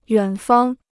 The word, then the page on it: 远方: yuǎn fāng